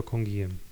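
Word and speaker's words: Canguilhemem: E, natomiast później, słuchając e, Francuzów i Francuzek, a im wierzę, jak należy czytać pewne rzeczy po francusku, bardziej niż Polakom, bez ujmy dla Polaków, rzecz jasna, no oni wymawiali go Kongijem, tak, Kongijem, mhm, ale mhm. – y, słyszałem też różne inne, y, może nie jakieś diametralnie inne, tylko niektórzy nadal mówią Kongilem.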